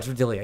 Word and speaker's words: D'Elia: Oh, D'Elia, yeah. I'm sorry. I got his name wrong.